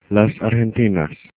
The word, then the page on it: argentinas: Spanish